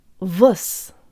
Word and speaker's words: воскресенье: The O sounds like a schwa. Let's say that first syllable with good Russian default mouth position. Jaw slightly forward, tip of the tongue behind the bottom teeth, lips rounded and protruded. Vus. Vus. Now think of how we call cats in the U.S. Here, kitty, kitty, kitty, kitty. Say kitty really fast, but emphasize the second syllable instead of the first. Kitty. Kitty. Now make the I disappear altogether, and we'll have something very close to the second syllable.